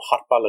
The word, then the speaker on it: Harpa: So Harpa, like you pronounce it.